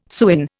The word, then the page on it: sewin: Indian